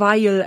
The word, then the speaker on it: Vial: Und zwar ist die Rede von Weil. Wenn ich das ausspreche, hört sich das an wie das deutsche Wort Weil halt. Es ist aber Weil, also V-I-A-L aus Los Angeles sind die.